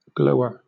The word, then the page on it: glower: British English